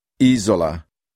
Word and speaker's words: isola: Some words are stressed on the third to the last syllable. – Isola. Giovane. Gomito.